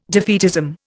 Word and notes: defeatism: Indian

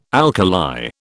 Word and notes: alkali: British English

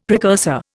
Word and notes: precursor: Indian

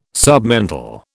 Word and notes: submental: British English

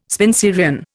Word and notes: Spenserian: Indian

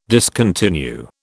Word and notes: discontinue: British English

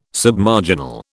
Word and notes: Submarginal: British English

